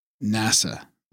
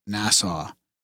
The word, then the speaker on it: NASA: Graham, it's NASA, not Nassau.